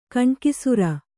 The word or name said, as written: ಗಣಾಕ್ಷರ